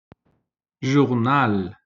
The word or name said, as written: journal